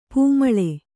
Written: ಪೂಮಾಲೆ

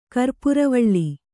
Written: ಕರ್ಪುರವಾಳೆ

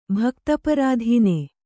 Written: ಭಕ್ತಪರಾಧೀನ

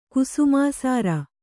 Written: ಕುಸುಮಾಸಾರ

